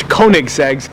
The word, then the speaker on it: Koenigsegg: Oh, we got the Koenigsegg, Swedish Koenigseggs.